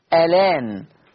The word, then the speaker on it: الآن: هشيل الهمزة واخد الفتحة حطها على اللام فتبقى لام مفتوحة فتصير ألان، فتصير ألان.